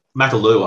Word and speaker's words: Makalou: I was going to say, mate, we can't skip number eight, old Sekou Makalou. Mate. – Makalou, I believe. – Okay. – So nice. When in doubt and pronouncing French names, Makalou, you just let's get the accent on.